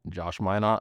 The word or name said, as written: Josh Minott